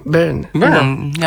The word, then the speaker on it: Byrne: Byrne.